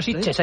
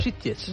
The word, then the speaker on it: Sitges: Sitges. No ha dicho Sitges, ha dicho Sitges. – Lo ha dicho muy bien. – Lo ha dicho muy buena pronunciación catalana.